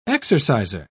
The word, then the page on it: exerciser: British English